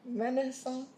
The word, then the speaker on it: Renaissance: How do you pronounce it? Child, you're asking the wrong person. – Renaissance. It's French. It's French.